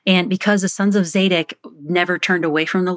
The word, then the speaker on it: Zadok: and specifically to the descendants of Zadok, and I think I'm pronouncing that right.